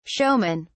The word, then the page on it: showmen: British English